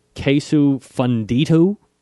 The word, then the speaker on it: queso fundido: All right, so we usually start with an appetizer, and tonight it looks like it's starting with garlic and mushrooms queso fundido. – That's it, yes. – Okay, did I say that right? – That's how I would have said it, so yes.